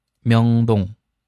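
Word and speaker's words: 명동: 下一个呢是明洞，韩语发音 명 동， 명， 명 동，其中第一个字圆圈也是，对吧？第一第二字都是圆圈，后鼻音收音， 명， 명 동， 명 동。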